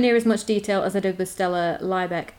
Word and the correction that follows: Liebeck: Is it L-I-E? – Yes. – I think it's Liebeck then.